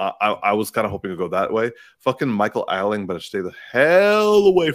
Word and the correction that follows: Ealy: How do you pronounce it? – Ealy. – Ealy. – Yeah.